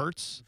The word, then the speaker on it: Wirtz: Yeah, Wirtz.